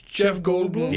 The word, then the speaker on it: Jeff Goldblum: Jeff Ballbloom. – Oh, no. – Jeff Goldblum? – Yeah, I said it wrong.